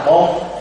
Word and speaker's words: Bụt: Chữ Phật á lẽ ra phải đọc là Bụt.